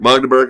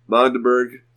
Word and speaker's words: Magdeburg: The very first one was Magdeburg? – Magdeburg. – I said it wrong. Sorry. Magdeburg.